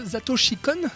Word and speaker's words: Satoshi Kon: Perfect Blue, alors de Satoshi Kon, c'est comme ça Est-ce que je le prononce bien Tu le prononces très bien.